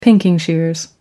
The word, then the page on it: pinking shears: American English